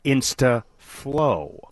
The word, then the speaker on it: Insta-flow: Insta-flow. Rhymes with slow.